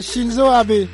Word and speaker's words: Shinzo Abe: Et le Comité international olympique CIO a accepté, a annoncé le premier ministre japonais Kenzo Abe, selon la même source. J'espère que j'ai bien prononcé. – C'est Shinzo Abe.